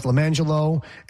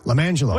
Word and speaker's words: Lime Jello: And she had a set of twins, and they were the names were pronounced LaMangelo and LaRangelo, but they were spelled Lime Jello and Orange Jello. L-I-M-E-J-E-L-L-O, LaMangelo.